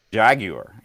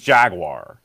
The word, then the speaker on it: Jaguar: Jaguar. You know, if you're from here, listen, it's Jaguar. That's how we say it.